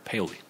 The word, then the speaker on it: Paoli: It's more like quicker, Paoli.